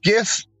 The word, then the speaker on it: GIF: Anyway, it's pronounced GIF. Have a good one. – There you go, Charles. It's pronounced GIF. – And his accent, it sounded like he said Jeff.